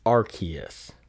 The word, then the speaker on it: Arceus: In the 12th Pokemon movie, it is pronounced Arceus rather than... Some people say Arceus, because I guess it looks like Zeus. – Yeah. – Arceus, and not Arceus. I say Arceus, but also I think it was in Pokemon Sun and Moon, they had a system that was the RKS system, the letters RKS. – Okay. – And if you say RKS, Arceus.